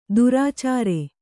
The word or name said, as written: ದುರಾಚಾರೆ